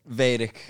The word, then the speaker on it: Vedic: Vedic. But yeah. Chants, like Vader. Like Darth Vader.